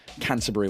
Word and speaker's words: Canterbury: Donc, naissance à Canterbury, comment vous prononcez-vous? – Canterbury, ouais.